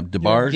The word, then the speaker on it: DeBarge: I used to call it DeBarge, but yeah, DeBarge. Yeah, it's French.